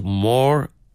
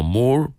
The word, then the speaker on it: Moore: Por ejemplo, la estupendísima Dot Moore. Yo lo hubiera pronunciado como Moore porque hay dos O's, pero el maestro de ceremonias, que es anglófono el hombre, uno de los músicos, pues dice Moore, así que yo, donde hay capitán no manda marinero, obviamente.